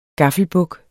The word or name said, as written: gaffelbuk